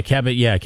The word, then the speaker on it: Caballero: Did I say it right? Caballero? – Okay, Caballero.